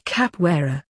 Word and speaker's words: capoeira: I love doing capoeira, pronounced capoeira. It started in my country, Brazil, about 500 years ago.